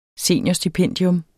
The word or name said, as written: seniorstipendium